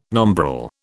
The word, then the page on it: nombril: British English